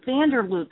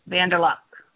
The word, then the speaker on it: Vanderluck: When I look at it, I think for some reason Vanderloot, and I know that's wrong. I don't know. Anyway, pronounce your name for everyone. – Okay, so I told you I pronounce it incorrectly, but for Americans, it's Vanderluck.